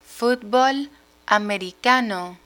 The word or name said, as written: futbol americano